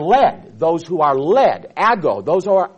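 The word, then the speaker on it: ago (ἄγω): It's translated from a Greek word ago, A-G-O, long O, ago.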